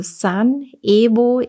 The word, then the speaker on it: Sun Evo: Wahrscheinlich habe ich das unge ähm, unge ungenau ausgesprochen. Also San Evo EVO News auf Telegram.